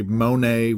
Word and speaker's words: Monet: But if you ask me to compare, let's say, Monet with Manet, the only thing I'm pretty sure of is that you're not supposed to pronounce the T in either one.